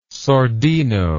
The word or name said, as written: sordino